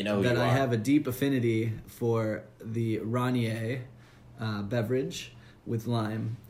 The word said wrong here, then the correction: Rainier: For those who aren't privy to the correct pronunciation, it's Rainier.